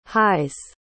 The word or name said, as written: highs